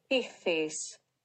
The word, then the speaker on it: Ichthys: 这个字怎么发音呢？Ichthys.